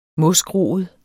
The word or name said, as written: mosgroet